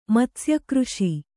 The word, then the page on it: ಮತ್ಸ್ಯಕೃಷಿ: matsya křṣi